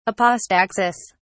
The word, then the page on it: apostaxis: British English